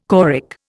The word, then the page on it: choric: Indian